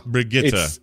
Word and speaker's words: Brigitte: Brigitte. It's because she's Swedish, and that is, I mean, I'm sure the Swedes listening to this are going to go like, oh my God, stop making a fool of yourself. But that's more or less how you pronounce it.